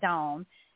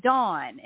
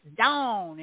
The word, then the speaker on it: Dawn: that's how I pronounce my name. But people a lot of other places, like Pam, for example, call me Dawn. And it's like, it's Dawn. It's put the on in it.